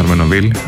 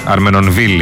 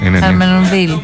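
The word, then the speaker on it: Armenonville: Προφέρεται το νι, το Αρμενοβίλ. Αρμενονβίλ είναι. – Αρμενονβίλ.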